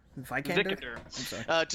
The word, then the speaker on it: Vikander: Alicia, how do you pronounce the last name? – Vikander? – Vikander, to star in the upcoming reboot.